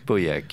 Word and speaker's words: Pauillac: Pauillac, yes.